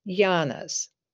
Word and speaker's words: yana: Because if we look at the Buddha's teachings in general, they do fall into what are called the three yanas, which is spelled Y-A-N-A.